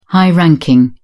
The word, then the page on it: high-ranking: British English